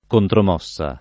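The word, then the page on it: contromossa: italiano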